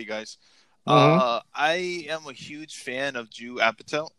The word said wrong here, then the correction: Judd Apatow: Judd Apatow.